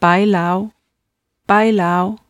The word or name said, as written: bailao